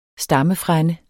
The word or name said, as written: stammefrænde